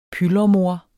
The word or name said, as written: pylremor